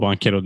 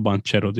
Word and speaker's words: Banchero: Zaten o yüzden adı Bankero diye okunuyor. Banchero diye okunmuyor.